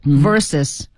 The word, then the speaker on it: versus: 来，对阵 Brazil versus VS, 不能在口语里读成 VS. – Right. – 是 versus.